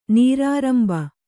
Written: ನೀರಾರಂಭ

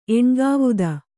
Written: ಎಣ್ಗಾವುದ

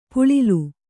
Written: ಪುಳಿಲ್

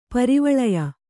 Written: ಪಾರುವೀಳೆಯ

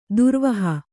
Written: ಧೂರ್ವಹ

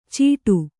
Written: ಚೀಟು

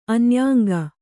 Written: ಅನ್ಯಾಂಗ

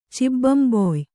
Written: ಚಿಬ್ಬಂಬೊಯ್

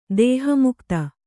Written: ದೇಹಮುಕ್ತ

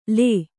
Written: ಳ

